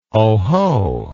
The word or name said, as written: oho